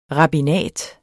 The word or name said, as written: rabbinat